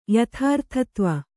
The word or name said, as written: ಯಥಾರ್ಥತ್ವ